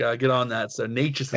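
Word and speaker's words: Nečas: And Frank, I finally found out how to pronounce uh, uh, the number 88 on Carolina, it's it's it's Natchez. It's spelled N-E-C-A-S, but it's pronounced Natchez.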